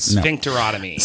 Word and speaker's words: sphincterotomy: Did I say that right? – Sphincterotomy. No.